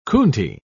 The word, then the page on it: coontie: British English